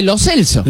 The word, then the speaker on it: Lo Celso: Toda la vida le dijimos Lo Celso. – Y que se dice, pronuncia Lo Celso. – Y él dice Lo Celso.